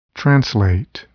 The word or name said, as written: translate